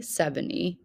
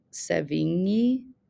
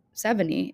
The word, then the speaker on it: Sevigny: I have always in my head said Chloe Sevigny, which can't be right. So Sevigny is what we're going to go with.